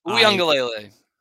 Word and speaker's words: Uiagalelei: Anybody, DJ Uiagalelei, don't know if that's how you say his name. – Uiagalelei.